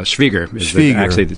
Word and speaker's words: Schwieger: Yeah, Schwieger. Technically the correct German pronunciation.